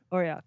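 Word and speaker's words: Oireachtas: Can you pronounce that? Oratacs? Oratracs? Oriacts? – Yeah, Oriacts.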